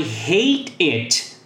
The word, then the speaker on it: hate it: E preste atenção na pronúncia. Hate it. Eu não vou pronunciar esse E aqui. Eu vou simplesmente conectar essas duas palavras com aquele mesmo som de R. Daí vai ficar de vara, aro, caro. Hate it.